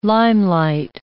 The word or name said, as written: limelight